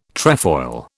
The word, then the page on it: trefoil: British English